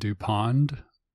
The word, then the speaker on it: Dupond: In French, their names are DuPont and DuPond, but pronounced in French, those are pronounced the same. So yes, it's like DuPont and DuPont, probably.